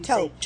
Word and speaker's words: taupe: Okay, taupe, which seems like a really ridiculous way to pronounce T-A-U-P-E. – I know. – We really expect people to come from other countries and learn our language, and we have these crazy pronunciations of T-A-U-P is taupe. – Yep, I know. – Taupe is T-O-P-E. Taupe.